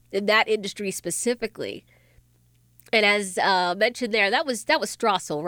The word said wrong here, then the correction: Stossel: Was that John Strassel? It sounded like him. Stossel. That's how you say it?